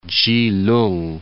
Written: Chi-lung

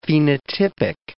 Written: phenotypic

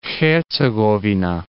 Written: Hercegovina

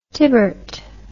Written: tibert

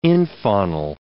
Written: infaunal

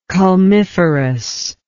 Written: culmiferous

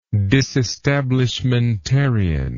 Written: disestablishmentarianism

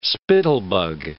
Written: spittlebug

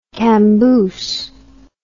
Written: camboose

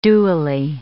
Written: dewily

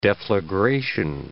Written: deflagration

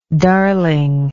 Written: darlingness

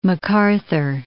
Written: macarthur

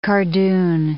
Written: cardoon

